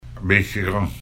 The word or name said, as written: Biigtigong